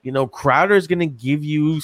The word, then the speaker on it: Crowder: It's pronounced Crowder. It's French. Get it right.